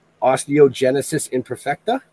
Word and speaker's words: Osteogenesis imperfecta: Osteogenesis imperfecta? – Actually, you nailed it.